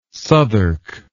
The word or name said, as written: Southwark